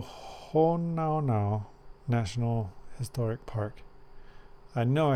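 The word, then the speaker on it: Hōnaunau: So it's Honaunau is probably the correct way to say it, right? But I call it Honaunau.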